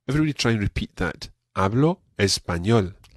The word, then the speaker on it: Hablo: Now, one thing we've got to watch with this phrase is the pronunciation of the letter B. It's not a very plosive B like B. It's more Hablo español.